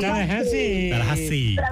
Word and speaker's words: Tallahassee: Tallahassee.